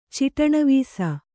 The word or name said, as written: ಚಿಟಣವೀಸ